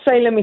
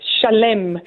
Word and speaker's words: Shalem: but we have to pronounce it correctly because it's not Salem, it's Shalem.